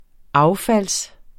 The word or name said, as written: affald